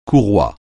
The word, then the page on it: courroie: French